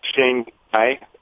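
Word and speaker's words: Shuanghui: I think if you're Shineway, if you're the Shanghai, as it's pronounced, but Shineway as it's commonly known, you look at this as a great opportunity.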